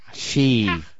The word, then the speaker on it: ki: You go, chi. – Okay, ki, chi. You can pronounce it a lot of different ways, but nevertheless, the point is, they're summoning.